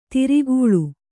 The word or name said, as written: ಧಾರೆಗಾಳೆ